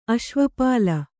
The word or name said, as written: ಅಶ್ವಪಾಲ